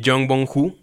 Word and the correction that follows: Bong Joon-ho: Bong Joon-ho, perdón.